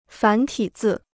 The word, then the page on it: 繁体字: fán tǐ zì